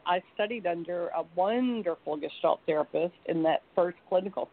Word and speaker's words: gestalt: I misspoke and mispronounced the word gestalt. I said jest, but it's gestalt.